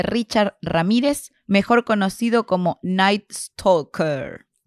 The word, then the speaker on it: Night Stalker: No, estaba escribiendo y le pregunté a Pokechu, ¿se dice Nice Talker? Y me dice, no, se dice Nice Talker.